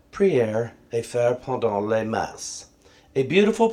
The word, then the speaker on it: Messe: M-E-S-S-E, how do you pronounce that? La Messe. Oh, I'm so close. It is Messe.